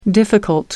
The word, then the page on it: difficult: American English